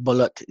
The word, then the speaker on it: balut: Does she eat blue? – Oh, balut, you mean? – Because that's a Filipino thing, right? – It's pronounced balut.